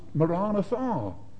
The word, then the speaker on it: Maranatha: Maranatha. I thought that meant he's coming. But then I discovered that it could be pronounced in a somewhat different way. It can also be pronounced Maranatha.